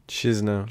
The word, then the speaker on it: Chișinău: Chișinău.